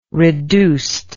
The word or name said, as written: reduced